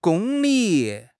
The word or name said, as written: gong1 li3